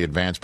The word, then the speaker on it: Advance: As somebody who also worked for the Staten Island Advance, the hometown newspaper, the word is really advanced, but we in Staten Island put the accent on the first syllable, Advance.